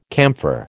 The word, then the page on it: camphor: American English